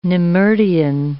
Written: nemertean

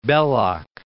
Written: Belloc

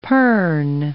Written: pirn

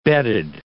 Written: bedded

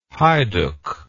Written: heiduc

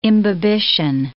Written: imbibition

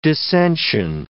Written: dissention

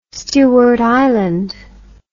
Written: Stewart Island

